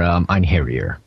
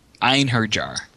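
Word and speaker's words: Einherjar: That is for Ein Harrier. – Oh, okay. Well, that's cool. – It's pronounced Ein Her Jar. – In her jar? – No, Ein it. Ein Her Jar.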